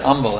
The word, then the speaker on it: Humble: They pronounced it Humble, actually.